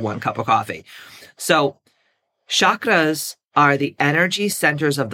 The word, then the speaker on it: chakra: First of all, what are the chakras? Okay, or the chakras. Chakra is how you pronunciate it. That's how you pronounce it in Sanskrit.